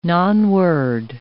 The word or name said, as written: nonword